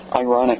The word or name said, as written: ironic